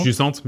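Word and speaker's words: Jusant: Euh, alors, je n'ai jamais su comment le prononcer. Jusante Jussante. bien sûr.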